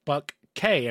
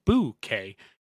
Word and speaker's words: bouquet: Well, it's funny because I was like, oh, they it's like pronounced buck K. And I was like, no, it's pronounced boo K.